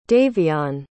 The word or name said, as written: daveon